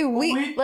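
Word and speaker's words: oui: You don't pronounce the O. Like when you say weed, like if weed, that's what you're saying.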